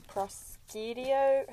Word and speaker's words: prosciutto: And I'm about to open these prosciutto.